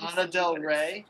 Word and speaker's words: Lana Del Rey: Lana De La, I can't say her name. I always thought it was a Trixie Mattel. – Lana Del Rey, yeah.